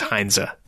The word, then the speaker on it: Heinze: I'm not sure if I said that right, to be honest. – Heinze. I've heard a few different pronunciations this past week. You know, back in the day, 06 World Cup, and I think he moved to Manchester United shortly thereafter. It was always Heinze back then.